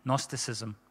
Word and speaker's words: Gnosticism: It's probably a silent G. Gnosticism, all right?